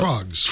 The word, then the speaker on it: Troggs: Was it the Throgs? Am I saying that right or not? – Troggs. – Troggs. – Like troglodyte.